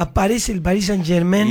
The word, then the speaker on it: Saint-Germain: Exacto. Saint-Germain. Pronuncie bien, querido.